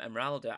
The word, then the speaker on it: Emeralda: And this one has been trademarked and pronounced with an A, so Storm Emeralda.